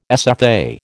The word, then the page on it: SFA: American English